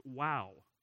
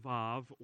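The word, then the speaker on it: wow (ו): Because Hebrew scholarship says that it's actually pronounced wow, okay? Now, there are others that pronounce it vav or whatever, but again, a recent one said it is wow.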